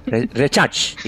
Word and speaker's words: Rezák: How do you pronounce Yes, it's Rezák. – Rezák, exactly. That is the correct pronunciation of Stanislav Rezák.